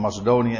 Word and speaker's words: Macedonië: Dus wat, eh, wat gebeurde daar in de streken van, van Macedonië, zeg ik het goed? Ja, van Macedonië en Thessalonica.